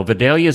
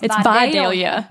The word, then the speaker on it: Vidalia: You didn't pronounce it right. It's Vidalia. – Vidalia. – Vidalia. – Vidalia. Is that right? – Yes, okay.